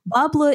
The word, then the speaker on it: Bas Bleu: So our sponsor is Bas Bleu, which is spelled B-A-S-B-L-E-U.